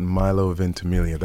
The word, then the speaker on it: Milo Ventimiglia: I do believe that Milo Ventimiglia, that's how you pronounce it, right? – Sure is, Milo Ventimiglia.